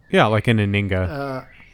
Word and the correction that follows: Anhinga: Anhinga. – Anhinga, yeah.